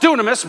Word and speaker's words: dynamis: The word is dunamis. It's pronounced dunamis, but it's spelled D-Y-N-A-M-I-S.